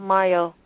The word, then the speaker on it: Mayo: Yes, I have a Facebook page and an email, and it's spelled S as in Sam, A-N-I-Y-Y-A-H, and then my last name, like Cinco de Mayo, M-A-Y-O.